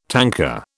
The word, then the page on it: tanka: British English